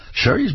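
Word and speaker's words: Shari's: That's Sherry, spelled S-H-A-R-I, but pronounced Sherry, as in have a very merry Sherry's Berries Christmas.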